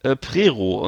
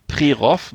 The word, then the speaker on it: Prerow: Und da kann man da gibt es einen Campingplatz, der heißt das der Regenbogen-Camping Prerow, geschrieben für Westdeutsche Prerow mit OW am Ende, wird aber O ausgesprochen, so wie die ganzen Orte.